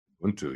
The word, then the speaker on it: Ubuntu: Is it Ubuntu? – Ubuntu, yes, of course.